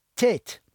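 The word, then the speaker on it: Teth: And it's pronounced Tet, T-A-Y-T, Tet.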